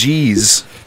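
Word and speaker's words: geas: Is it geez with a J? Is it gibbering? – it's nice to know you listen to the show. – Maybe though. It's geez. I was under a geez. – that makes sense. Now you go, geez.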